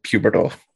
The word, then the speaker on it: pubertal: I was like, it looks like puberty, so I'm just going to go with pubertal. – Yeah. – No, that's how I say it.